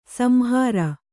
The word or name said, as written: ಸಂಹಾರ